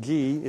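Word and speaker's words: ge (γῆ): The word land, hey, the word land is in the Greek, it's pronounced ge. It's just simply spelled out in English, G-E.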